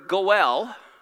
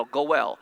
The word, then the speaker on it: Goel: When you add the letter Gimel, the G sound, you now get the word Goel, G, you know, the vowel is silent and the L, Goel. Do you know what this word is?